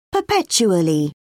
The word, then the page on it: perpetually: British English